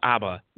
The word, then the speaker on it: ABBA: The first pronunciation. ABBA or ABBA. – It's ABBA.